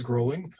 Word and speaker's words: Groeling: My name's James Groling.